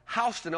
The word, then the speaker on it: Houston: In Ohio, there's a place called Houston, Ohio, spelled the same way as Houston.